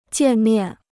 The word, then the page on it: 见面: jiàn miàn